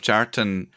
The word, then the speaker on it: Kjartan: Yeah, and in fairness to yourself, Jartan Poskitt, both in the pronunciation or if people hear it and if they see it written, It's an amazing name. It's spelled Kjartan.